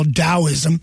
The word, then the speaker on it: Taoism: It didn't have too many hit records on it, but it was a great, heavy album about, you know, the Chinese religion, Taoism, which some people call Taoism because the T might be pronounced as a D, but whatever.